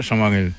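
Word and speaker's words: Chamarel: Tellement que Chamarelle a un nom prononcé, on doit dire Chamarelle.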